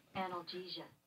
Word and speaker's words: analgesia: And he sent this chart about the more you take, how you move from an analgesia. Am I saying that right? – Analgesia.